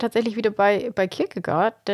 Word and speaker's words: Kierkegaard: Also ich glaube, ich habe mal, also das, man sagt nicht Kirke, sondern eher so Kirche. Also so ein bisschen angehaucht, aber vor allem Goa. – Goa, ja, genau. Also Kierkegaard.